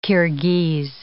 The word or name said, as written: Kirghiz